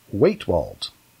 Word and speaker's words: Wetewold: It is Waitwold. It is spelled W-E-T-E-W-O-L-D. Waitwold.